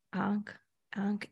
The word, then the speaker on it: Ankh: Ankh. No, I'm saying right. A-N-K-H. Ankh. Okay. – I'm just not educated on it, so I have no idea. – I'm saying it probably Ankh. A-N-K-H.